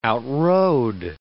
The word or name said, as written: outrode